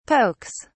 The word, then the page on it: pokes: British English